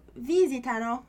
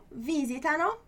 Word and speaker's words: visitano: Olha aí a pronúncia de novo, mudando um pouquinho, né? Em português a gente só tem as três últimas sílabas da palavra, né, para ter acento. Em italiano a gente tem até uma quarta sílaba, tendo um som mais forte, né? Então é visitano, não é visitano, ok?